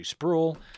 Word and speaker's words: Sproul: I believe it's actually Sproul. Rhymes with fool.